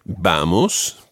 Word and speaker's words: vamos: Vamos, the stress on the first syllable, vamos a, and then the word for to travel is viajar.